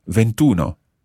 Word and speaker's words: ventuno: So we drop the E sound at the end of venti and add in uno. Ventuno.